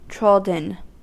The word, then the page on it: trodden: American English